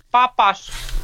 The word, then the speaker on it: Papas: Do I pronounce your last name correctly? – Papas. – Papas, yeah, Papas. Okay, sounds like a French pronunciation.